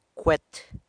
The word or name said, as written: cwe't